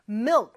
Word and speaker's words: milk: Milk 발음이 조금 어려울 수 있어요. mil 하면서 을 발음할 때는 혀가 윗니 뒤쪽에 와서 쫙 붙지만 뒤쪽, 혀의 뒤쪽은 목구멍을 약간 닿는 느낌을 주죠. 그러면서 크 살짝 해주는 거예요.